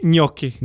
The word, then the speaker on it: gnocchi: Gnocchi. – Gnocchi. – So it's spelled G-N-O-C-C-H-I. Gnocchi. So that G-N makes it gnocchi. Sounds like Gucci.